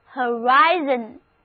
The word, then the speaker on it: horizon: 啊，这个 horizon 就是我们今天的话题， H-O-R-I-Z-O-N，重音放在第二个音节上，前一个 O 字母发 a 的音，中间的 I 是发 I 的本字母的音，后一个 O 字母不发音。 Read after me，跟我读这个单词，就是 horizon，地平线。